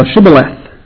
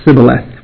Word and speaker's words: Shibboleth: Then they would say to him, "Say now Shibboleth." But he said, "Sibboleth," for you could not pronounce it correctly. Part of the Ephraimite dialect of the Hebrew language, that they were not pronouncing their sheens, they would pronounce seens.